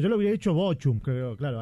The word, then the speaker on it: Bochum: Bochum.